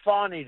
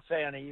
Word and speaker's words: Fani: Will you tell me, how's Fawny doing? You know, you're not allowed to call her Fanny, even though it's spelled F-A-N-I.